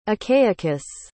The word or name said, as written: achaicus